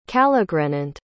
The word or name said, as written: calogrenant